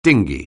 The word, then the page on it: dinghy: anglais